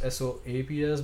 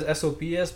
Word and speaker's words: Sops: If I write it as S O P S, people just say sops. And sops. yeah, like okay, that's not how you pronounce it.